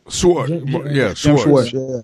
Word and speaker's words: Schwartz: I think I'm saying his name right. – Schwartz.